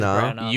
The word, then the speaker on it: Branagh: Kenneth Branagh. I thought you were going with Brana. – No, Branagh. Right? – You were going with Branagh. – Is it Branagh? – I thought it was Branagh.